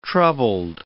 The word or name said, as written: troubled